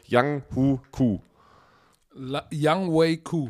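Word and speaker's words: Younghoe Koo: Young Wei Ku. – Ach, so wird er ausgesprochen. Ich vergesse es jede Woche.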